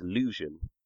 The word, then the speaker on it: Leuvsion: is Lusion, and is spelled L-E-U-V-S-I-O-N.